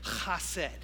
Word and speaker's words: chesed: Say, chesed. Now wipe the spit off their face that you just put on there, all right? But that's actually how you say it in Hebrew. It should make the listener get a little wet, you know? Chesed.